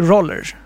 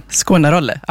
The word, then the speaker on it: Skåne Roll: Föreningen Skåne Roll, är det Roller kanske? – Skåne Rolle, ja. – Skåne Rolle, aha, du skulle uttala så.